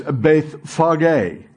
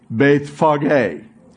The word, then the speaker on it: Bethphage: Now, when they drew near to Jerusalem, and the way to pronounce this is Bethphage. Bethphage. Can you say that with me? Bethphage, and to Bethany, and in the Greek or Aramaic, that is Bethania.